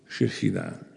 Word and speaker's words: Shechina: De Shechina verschijnt. Klemtoon op de laatste lettergrepen, zoals gewoonlijk in het Hebreeuws. Shechina.